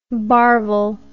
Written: barvel

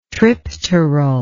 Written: tripteral

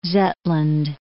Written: Zetland

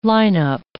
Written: lineup

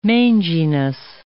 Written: manginess

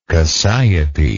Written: cassiope

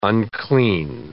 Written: unclean

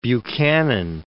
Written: Buchanan

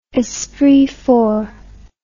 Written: esprit fort

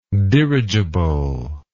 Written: dirigibility

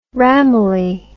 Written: ramillie

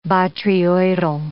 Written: botryoidal

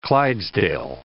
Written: Clydesdale

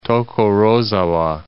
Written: Tokorozawa

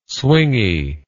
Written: swingy

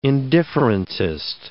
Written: indifferentist

